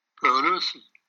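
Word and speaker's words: Pelosi: این خانم پلوزی یا پلوزی چجور تلفظ میکنن؟ – پلوسی.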